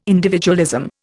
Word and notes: individualism: Indian

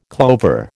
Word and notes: clover: American English

